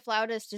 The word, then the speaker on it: flautist: Like very, you know, I feel like a flautist is like detail oriented and like on top of it and like paying attention. – I love right? – how you pronounce that correctly, by the way. Flautist.